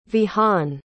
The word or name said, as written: Vihaan